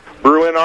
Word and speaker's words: Bruin: Bruin Army. – Yeah. – BN Army. Get your money up. – You don't there. – Even know. You don't even know how to say it.